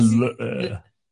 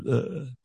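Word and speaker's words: leu: Nah, the lur.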